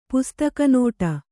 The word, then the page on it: ಪುಸ್ತಕನೋಟ: pustaka nōṭa